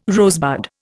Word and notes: rosebud: Indian

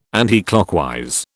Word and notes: anticlockwise: British English

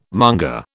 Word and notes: monger: British English

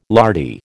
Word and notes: lardy: American English

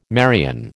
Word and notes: Marian: American English

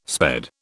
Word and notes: sped: British English

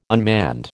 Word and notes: unmanned: American English

